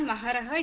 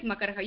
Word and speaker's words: Makaraha: It's not Maharaha, it's Makaraha. You are saying Ka in place of Ha and Ha in place of Ka.